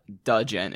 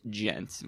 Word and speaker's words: djent: So a big thing that's kind of been popular nowadays is called djent, which is spelled D-J-E-N-T. So it's not Dudgeon, it's Gent.